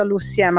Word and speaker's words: Luci M: Mi chiamo Lucia Murano, in arte Luci M, si pronuncia Luci M alla francese.